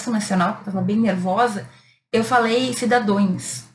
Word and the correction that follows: cidadãos: e o correto é cidadãos.